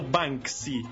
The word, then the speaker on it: Banksy: Che poi va andrebbe pronunciato Banksy, perché si scrive Bank come banca, uh-huh, e Y, tutto attaccato, Banksy.